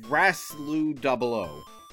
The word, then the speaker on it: Graslu00: It's Graslu 00, GRASLU00.